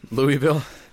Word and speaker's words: Louisville: So, to Louisville. Is that how you pronounce it? – Louisville.